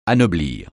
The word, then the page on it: anoblir: francais